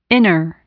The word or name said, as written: inner